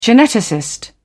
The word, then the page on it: geneticist: British English